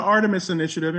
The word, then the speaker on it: Artemis Initiative: No, it's the Artemis Initiative.